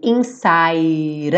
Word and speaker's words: inside: I know we're different, but deep inside us o inside, o E não vai ser pronunciado, e o D ficou rararã.